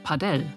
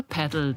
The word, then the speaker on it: Padel: Daher wird der Name auch manchmal spanisch ausgesprochen, Padel. Inzwischen hat sich bei den 300.000 Menschen, die es in Deutschland spielen, aber die englische Variante Paddle durchgesetzt.